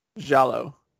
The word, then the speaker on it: Giallo: And then he also dabbled in the, I can never pronounce this, the Giallo? – Giallo.